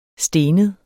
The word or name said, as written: stenet